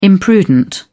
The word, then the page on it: imprudent: British English